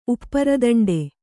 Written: ಉಪ್ಪರದಂಡೆ

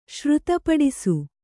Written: ಶ್ರುತಪಡಿಸು

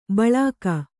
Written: ಬಳ್ಳಿಕ್ಕು

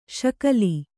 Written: ಶಕಲಿ

